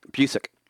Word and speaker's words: Busiek: How do you say his name? – Busiek. – How do you say Gyro? – Hero.